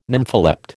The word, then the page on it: nympholept: American English